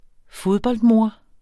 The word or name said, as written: fodboldmor